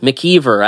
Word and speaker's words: McEver: McEver, I said that right, correct? – Yes, you did.